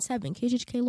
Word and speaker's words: KJHK: Oh, I just found out that I was speaking it wrong. One of my friends here, she told me that it's actually 90.7 KGHK Lawrence. I was saying it wrong the whole time.